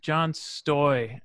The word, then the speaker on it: John Stoj: So it's pronounced John Stoj, and I've lived with that mispronunciation throughout my almost 50 years so far.